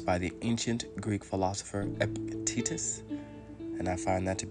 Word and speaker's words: Epictetus: E-P-I-C-T-E-T-U-S.